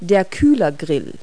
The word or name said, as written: grille